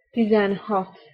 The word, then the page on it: tizenhat: Hungarian